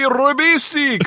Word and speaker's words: Ribisi: Angelina Jolie and Giovanni Ribisi co-star.